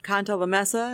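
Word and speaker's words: Cantalamessa: Now, this retreat was headed up by Capuchin Father Canta Lamessa.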